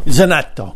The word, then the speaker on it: Zanetto: Christina Zanetto. Did I say your name right, Christina? – Christina Zanetto. – Zanetto. Of course. There you go. It's more Italian, right? – Yes, I there am originally from Italy.